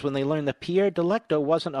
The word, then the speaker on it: Delecto: He's a Pierre Delectico bot, or Delecto, may I say.